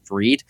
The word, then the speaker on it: Fried: My last name is spelled F-R-I-E-D, spelled like fried, but pronounced Freed.